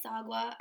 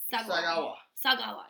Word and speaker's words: Sagawa: So on the afternoon of June 12th in 1981, Issei Sagwa, I have no fucking clue how to pronounce his name, and I think it's Issei. – It's Issei. – Issei. – Sagawa.